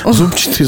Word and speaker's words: зубчатый: У нас есть, например, клетчатый, но при этом строгая норма зубчатый. А ударение зубчатый раньше вообще не допускалось, но сейчас э, во многих словарях зубчатый и зубчатый даны как одинаково правильные варианты.